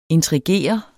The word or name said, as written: intrigere